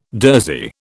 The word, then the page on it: durzi: British English